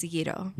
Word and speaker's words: gyro: Is it gyro? – I say it that way because that's how Americans should say it. But okay. In Greek, it's gyro.